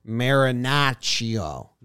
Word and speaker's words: Marinaccio: When you go to his Baseball Reference and you look at his how to pronounce his name, it's awesome. – Yeah. – Marinaccio. – Joe's rolls the R's. It sounds pretty cool. – Marinaccio?